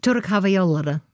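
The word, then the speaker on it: Türk Hava Yolları: Das ist, können wir nicht, dieses I ohne I-Punkt. Türk Havayollere zum Beispiel.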